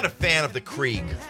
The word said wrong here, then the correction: Keurig: It's pronounced Keurig.